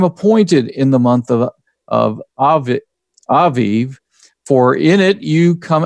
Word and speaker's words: Abib: And as you look at that word, Aviv, A-B-I-B, it is a Hebrew word, and for the month, the first month. And I was looking up today how it's pronounced. It's Aviv, and that's a more accurate pronunciation.